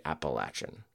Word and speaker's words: Appalachian: And you can't go long A in Appalachia. You have to say Appalachian, which I think is a specific Appalachian thing, because in the lower South, they would lay out that A.